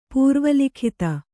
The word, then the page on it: ಪೂರ್ವಲಿಖಿತ: pūrva likhita